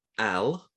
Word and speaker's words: Al: So it's called Al. I believe that's how it's pronounced. – Just A-L. – Just A-L, yeah.